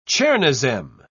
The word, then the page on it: chernozem: British English